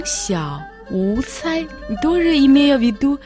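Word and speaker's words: 小: «Сяо» в третьем тоне.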